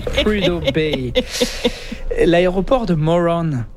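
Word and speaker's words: Morón: Où c'est le Moron Aéroport. – Ah, vrai prononciation. – Un petit accent, là. – Ouais, à l'espagnol, là.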